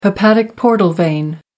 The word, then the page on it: hepatic portal vein: American English